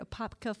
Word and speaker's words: Apopka: She's raised in, I have to say it right, Apopka, Florida.